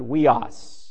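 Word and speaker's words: huios (υἱός): The word for son in the Greek language is the word huios, spelled and in, transliterated in English, English U-I-O-S, but it's pronounced huios.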